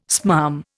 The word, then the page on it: smarm: Indian